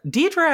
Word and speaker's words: Deirdre: Deirdre actually sounds right, and I can go with that. It sounds like the way that we pronounce Deirdre. It's more that Deirdre as a name is D E, is it E I? – Yeah. – D E I R D R E is Deirdre.